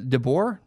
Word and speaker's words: DeBoer: The San Jose Sharks fired their head coach, Peter DeBoer, because DeBoer, we'll call him DeBoer. That's how the guy, I was watching an NHL game, literally because I was trying to figure out how to pronounce his name. The announcer called him DeBoer.